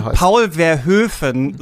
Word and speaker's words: Paul Verhoeven: Paul Und Verhoeven ich finde Paul Verhoeven so schön als Aussprache dieses Namen.